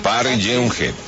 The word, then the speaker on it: Park Geun-hye: La justicia de Corea del Sur destituyó a su presidenta, Park Geun-hye. Así se pronuncia, ¿no? – Park Geun-hye, sí.